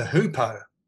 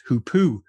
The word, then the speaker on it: hoopoe: I, I also pronounce it hoopoe, and uh, I know that it's probably incorrect. And the, the correct pronunciation is to me the frankly ridiculous hoopoo.